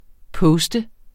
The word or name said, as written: poster